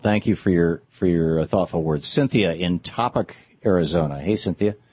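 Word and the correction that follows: Topock: Actually, it's pronounced Topok, but never mind.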